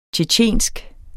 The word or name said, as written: tjetjensk